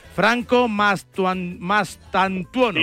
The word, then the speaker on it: Franco Mastantuono: Franco Mastantuono, sí.